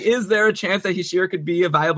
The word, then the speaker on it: Hischier: Is it Hischier? – Yeah. – There you go. I nailed it.